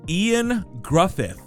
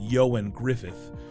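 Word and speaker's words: Ioan Gruffudd: Ian Gruffydd. which is it's written Ian Gruffeld, but it's pronounced Yoan Griffith.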